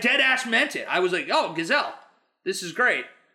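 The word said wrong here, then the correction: Gisele: I just said it wrong. I just called her a Gazelle instead of Giselle.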